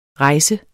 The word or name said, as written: rejsende